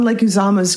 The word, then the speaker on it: Leguizamo: And they talk to the guy who's John, is it Leguizamo, I think is how you pronounce it? – Leguizamo? – Yeah. He was in the menu.